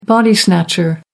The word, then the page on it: body snatcher: American English